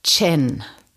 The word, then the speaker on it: Zhen: Wir sprechen über Chen. Genau, geschrieben wird es Z-H-E-N.